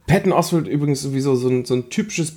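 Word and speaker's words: Patton Oswalt: Ich weiß gar nicht, wie spricht man aus? Peyton Oswalt? – Peyton Oswalt, ja.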